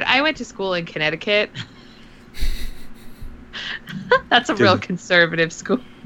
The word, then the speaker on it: Connecticut: Fun fact, Connecticut has a silent C in it. Didn't know that. – It does, Connecticut, yeah.